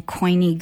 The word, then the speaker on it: Koine: When we talk about Mark, we talk about it being written in Koine Greek.